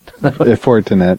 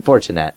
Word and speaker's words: Fortinet: With Juniper and Fortinet. Am I saying it right? – Fortinet. – Fortinet.